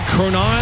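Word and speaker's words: Cronin: Courtney Cronon, I believe is how you pronounce her last name. NFL Nation Bears reporter. – Close enough.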